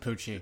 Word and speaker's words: Pucci: Hey, this is of course starring Jane Levy, Shiloh Fernandez, Lou Taylor Pucci, Jessica Lucas. – I'm glad you pronounce it Pucci. Those two C's can go anywhere.